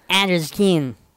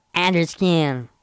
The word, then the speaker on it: Under the Skin: Under the Skin. – ¿Ah? – Under the Skin.